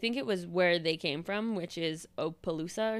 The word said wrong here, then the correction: Opelousas: or some shit. But okay. – Opelousas. I know how to say it because I fucking listen to a podcast.